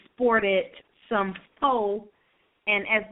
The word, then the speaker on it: faux: L-A-U-X. – U-X. – She said some fox. No, it's faux, not fox.